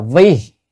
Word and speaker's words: vi: Chữ này chữ vi hoặc là vị, nhưng mà ở đây phải đọc là vi, là chứ nếu đọc vị là vì thì không được.